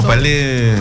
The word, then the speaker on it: Palais: Como Palais. – En realidad se pronuncia Palais.